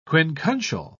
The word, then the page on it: quincuncial: British English